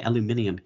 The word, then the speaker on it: aluminium: They've got a song called Aluminium, and that's how they say it.